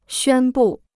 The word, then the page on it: 宣布: xuān bù